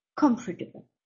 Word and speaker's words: comfortable: Ponga bien el acento en la primera sílaba, comfortable.